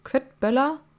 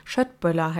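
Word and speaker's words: Köttbullar: Köttböller? Warte, Moment. Schöttböller heißen die Dinger, glaube ich, richtig ausgesprochen.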